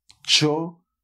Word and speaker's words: chorizo: Esto se pronuncia chorizo. Un chorizo. O sea, que nada de artes marciales, chorizo, o nada de gatos borrachos, chorizo. No, chavales, esto se dice chorizo.